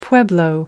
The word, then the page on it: pueblo: British English